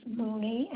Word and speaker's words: Mooney: Okay, and my last name is Mooney, M-O-O-N-E-Y.